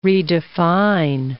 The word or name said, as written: redefine